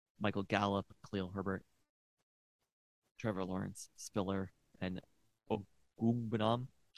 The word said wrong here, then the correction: Okwuegbunam: To clean that up, Okwuegbunam is how to pronounce that. Okwuegbunam.